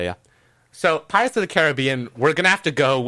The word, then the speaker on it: Caribbean: So, is it Caribbean or Caribbean? – Okay, so the place is pronounced Caribbean, but Pirates is always Pirates of the Caribbean. I don't know why.